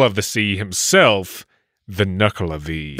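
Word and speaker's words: Nuckelavee: This one is called the Nuklevi. I believe I'm saying that right. Is that the correct pronunciation? – Yes.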